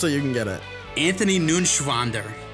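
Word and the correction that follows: Neuenschwander: Ah, you didn't pronounce it right. That's up. An- Anthony. Anthony Nunschwander. It's it's N E U E N. It's Neuen Schwander. Anthony Neuen Schwander.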